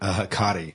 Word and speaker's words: Hecate: And you know, because nobody knew that Hecate was Hecate. Oh, they pronounce it Hakati. – Hakati? Okay, so Maybe that's how it's an old. – That's the way the book pronounced. Yeah. That's the way the audio book.